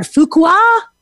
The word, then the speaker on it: Fuqua: Dori Clark is an adjunct professor at Duke University's, I don't know how to say the word, Fuqua? – That's right. Good. That works.